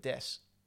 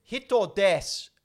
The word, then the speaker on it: desu: When a lot of the Japanese phrases, when you speak Japanese, you end it with des. But it's spelled D-E-S-U. – Desu? – The U is silent. – Oh, so des.